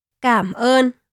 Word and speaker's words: cảm ơn: As Vietnamese is a tonal language, it is important to correctly pronounce the tones, as an incorrect pronunciation of a tone can change the meaning of a word and in turn the meaning of the phrase.